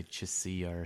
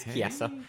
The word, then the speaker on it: Chiesa: You can say Chiesa, that you didn't say Chisilla or something like that. – I know. I watch MMA. I know it's Chiesa. – Hey. – Even though it's spelled kind of funny.